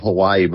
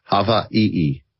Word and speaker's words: Hawaii: in Hawaii, which is the correct pronunciation of Hawaii, by the way, Hawaii.